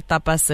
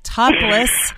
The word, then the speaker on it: tapas: But whenever I tell my friends, oh yeah, you know, if I don't pronounce it correctly, like in the Spanish way, people are like, you went to a topless bar? You know, you went to a topless. I was like, no, tapas, not topless.